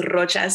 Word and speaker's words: Rocha: I always joke with my friends that if you can actually roll your R's, you can be friends with me. My last name is Rocha, so I always get them with that. And yes,